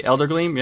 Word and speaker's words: Eldergleam: they have you go into Eldergleam Sanctuary. I'm saying that right? Come on everybody. Eldergleam, yeah? With the big tree. Yep. – For you, yeah, you're saying it right.